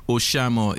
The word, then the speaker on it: Oshamo: Oshamo. O-S-H-A-M-O.